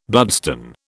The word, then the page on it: bloodstone: British English